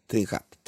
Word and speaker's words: trinxat: Jag, jag tror det är så det uttalas. T-R-I-N-X-A-T. Trinchert. Trinchat.